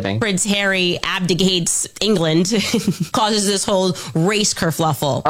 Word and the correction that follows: kerfuffle: Oh, no, it's kerfuffle. – We knew a kerfluffle happened. – Kerfuffle. – Because this dress kerfluffle. – Pronounced kerfuffle. Kerfuffle.